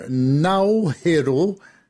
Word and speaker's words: Naoero: c'est-à-dire de passer de Nauru, un nom similaire, mais qui reflète mieux, selon les autorités, la prononciation initiale. Et ça donnerait donc Nauhero, N-A-O-E-R-O,